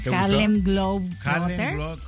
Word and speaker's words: Harlem Globetrotters: porque estaremos con los Harlem Globetrotters. O sea, ¿lo dije bien? – Harlem Globetrotters. – ¿Cómo se dice? – Glow es de globos. Globos trotando.